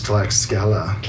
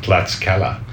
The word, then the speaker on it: Tlaxcala: How do you say it? – Linda told me it's Tlaxcala. – Tlaxcala.